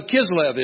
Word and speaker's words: Kislev: Of course, we all know when the month of Kislev is, right? You're from a Jewish background. Kislev. Okay, thank you for the pronunciation.